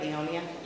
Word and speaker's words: Leonia: Did I say that right, Leonia? – Yeah.